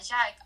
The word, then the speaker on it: JICA: Para se inscrever na Jaica, a Jaica, como se escreve J-I-C-A. – J-I-C-A. Se fala Jica, mas se lê Jaica, né?